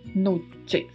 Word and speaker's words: noches: E o do CH se pronuncia com o som de tchau. Noches.